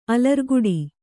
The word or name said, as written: ಅಲರ್ಗಟ್ಟು